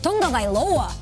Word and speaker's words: Tagovailoa: Wide receiver is really bleak, but we start with a quarterback, Tua Tagovailoa. Great matchup on Sunday. How did I say this yesterday? – Tua Tagovailoa.